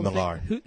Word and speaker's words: Millar: Mark Miller, your friend. – Millar. – I don't think it's Millar. – It is pronounced Millar. – Where did you see it pronounced Millar? – On Newsarama. I actually heard him. – He said his own name and he said it was Millar.